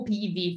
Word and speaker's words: Piwi: Dicevo, io ti ho invitata qui oggi a Spill Out Show per parlare dei vitigni Piwi. Allora, è eh, o Pivi, forse è meglio pronunciarlo con la W alla tedesca,